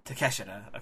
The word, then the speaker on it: Takeshita: That's the only reason I know how to pronounce that name, because I said Takeshita the first time I read that. – I always used to say Takeshita, too, but then I heard everybody pronounce it. I was like, oh, shit, I was doing it wrong this whole time.